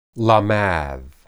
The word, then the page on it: lamad: hebrew